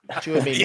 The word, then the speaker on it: Tchouaméni: Tchouaméni.